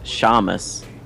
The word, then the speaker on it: Seamus: So this guy, whose name is Seamus, but he says pronounced Shamus, which... – Okay. That's weird. – It's S-E-A-M-U-S.